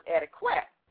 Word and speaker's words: etiquette: Now, you know, a lot of times, Denise, we hear people pronounce this word etiquette, right? – Mm-hmm. – And, of course, the reason for that is understandable because it's spelled E-T-I-Q-U-E-T-T-E, and the Q does make the qua sound. However, since it comes from a French word meaning ticket, I always say if you say the word etiquette slowly, you hear yourself say ticket.